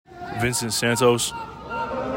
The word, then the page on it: Vincent Santos: vihn SENT SAN tohs